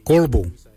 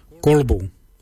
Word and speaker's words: goolybol: For the belly, we say korbu. Korbu. Korbu is spelled G-O-O-L-Y-B-O-L. Korbu. Korbu is your belly. Your belly button is your bil. Your bill. Your bill is spelled B-I-L-Y.